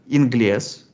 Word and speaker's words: inglés: На обоих этих языках английский произносится как «inglés», да? То есть, как вы понимаете, там нету «an», там есть «in», «inglés». И если мы заглянем в такое английское слово,